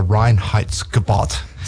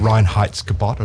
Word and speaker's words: Reinheitsgebot: And you pronounced it perfectly. – The Reinheitsgebot of 1516,